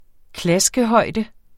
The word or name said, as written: klaskehøjde